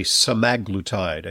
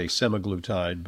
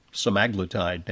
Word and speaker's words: semaglutide: And there's a whole raft now of weight loss and diabetes drugs that contain, and I believe the correct pronunciation these days, they want us to say semaglutide. I used to say semaglutide, but, you know, semaglutide now. Ozempic